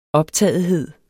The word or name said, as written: optagethed